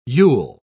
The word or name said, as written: yule